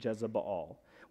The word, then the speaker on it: Jezebel: In its original Phoenician pronunciation, Jezebel.